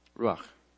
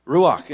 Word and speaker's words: Ruach: Does anybody know how to pronounce this Hebrew word? You know. Ruach. Ruach, it means wind, breath, mind, or spirit.